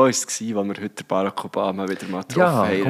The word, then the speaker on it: Barack: Gibt's schwierig bei vielen Namen, aber jetzt Barack ist auf der zweiten Silbe.